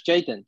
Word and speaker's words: Chaitin: George Chaitlin, was it? – George Chaitin. Yeah, Chaitin. I think it's Chaitin.